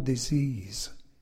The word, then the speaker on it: dis-ease: Il s'écrit « D-I-S-E-A-S-E ». Jeff l'écrit en deux mots, en introduisant un tiret entre « dis » et « ease ». Le mot se prononce alors « dis-ease » et prend un sens un peu différent.